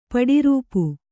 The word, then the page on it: ಪಡಿರೂಪು: paḍi rūpu